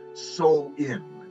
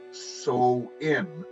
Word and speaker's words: Samhain: Now, what they were celebrating back then and the original name of Halloween was it's pronounced uh, well it's pronounced soul in but it's spelled s-a-m-h-a-i-n so sam hain mm-hmm. but it's mm-hmm. pr- pronounced so in which i found really really telling yeah.